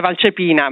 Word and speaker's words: Valcepina: Il cognome è pronunciato abbastanza bene, siamo da otto. L'accento è Valcepina, ma va benissimo così.